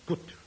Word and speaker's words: Qutb: Golconda, in its heyday, was indeed a center for precious stones, and was ruled over by a queen who was called Gut, which is spelt Q-U-T-B.